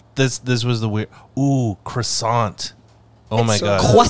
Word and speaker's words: croissant: T at the end of croissant is definitely silent, so do not say croissant.